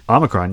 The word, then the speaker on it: Omicron: Is that how you say it? – Omicron, yeah.